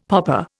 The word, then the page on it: poppa: Indian